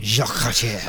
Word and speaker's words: Jacques Cartier: I don't know how you say it in French. It's like, it looks like Cartier, but it's like Cartier probably. – Jacques Cartier.